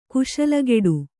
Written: ಕುಶಲಗೆಡು

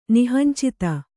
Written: ನಿಹಂಚಿತ

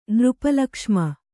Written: ನೃಪಲಕ್ಷ್ಮ

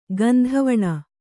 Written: ಗಂಧವಣ

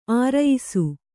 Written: ಅರೆಯಿಸು

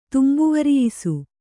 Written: ತುಂಬುವರಿಯಿಸು

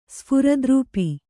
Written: ಸ್ಫುರದ್ರೂಪಿ

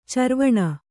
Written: ಚರ್ವಣ

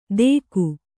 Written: ತೇಕು

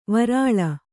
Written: ವಿರಲ

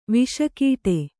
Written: ವೇಷಕಟ್ಟು

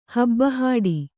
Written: ಹಬ್ಬಹಾಡಿ

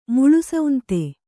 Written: ಮುಳುಸೌಂತೆ

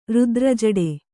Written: ರುದ್ರಜಡೆ

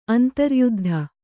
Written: ಅಂತರ್ಯುದ್ಧ

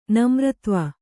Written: ನಮ್ರತ್ವ

